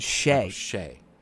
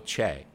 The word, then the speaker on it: Che: I know, everyone says I say it wrong, but... – Yeah, because you do. You just said Che. – Michael Che. – Yeah. – I need to hit that ch harder.